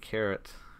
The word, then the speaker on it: Charat: And after that, I watched Panyo Panyo Digi Carrot. And yes, that is pronounced carrot.